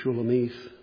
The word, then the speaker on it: Shulamith: It looks like Shulamith, M-I-T-H on the end, but it's pronounced like a long E, Shulamith. And she speaks of Solomon.